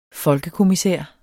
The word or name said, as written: folkekommissær